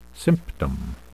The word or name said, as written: symptom